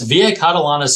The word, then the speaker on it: Via Catalana: Yes, Via Catalana Cellars. I talked a little bit earlier about it feels like you're going to another country. I'd say Via Catalana probably exemplifies this the most,